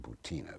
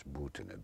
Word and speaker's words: Butina: Incidentally, as we talk, this young Russian woman, Marina Butina, sometimes pronounced here Butina, but it's Butina, B-U-T-I-N-A,